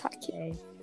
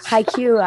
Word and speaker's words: Haikyuu: It's not pronounced Haikyuu. – Haikyuu, yeah, it is.